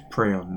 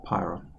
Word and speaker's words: prion: And it's Prion, not Pyron.